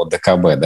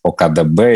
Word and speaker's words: ОДКБ: Я, кстати, еще обратил внимание, может быть, такой несерьезный момент, но в странах СНГ часто, кстати, путают аббревиатуру ОДКБ, да, там говорят ОКДБ или еще что-то.